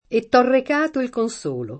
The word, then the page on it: consolo: kon S1 lo; Italian